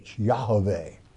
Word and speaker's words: Yahweh: So, but it is Y, in the Hebrew tongue, Yah, which is Y-A-H, not J-A-H, Y-A-H. There's no J's in the Hebrew alphabet. Y-A-H-A-V-E-H, Yahweh, okay? The correct pronunciation of the sacred name.